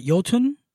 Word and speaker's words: Jotun: I bought Jotun, which is spelled Jotun. – Yeah. – J-O-T-U-N.